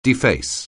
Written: deface

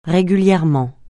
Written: régulièrement

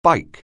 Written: bike

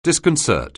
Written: disconcert

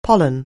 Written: pollen

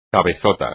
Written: cabezota

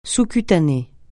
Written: sous-cutané

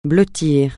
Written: blottir

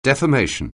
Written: defamation

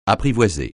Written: apprivoiser

